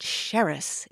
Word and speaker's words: Xeres: And that city's name was originally spelled X-E-R-E-S. And in the olden days, it had an S-H sound at the beginning of it. And the name of this beverage was adapted into English as Xeres.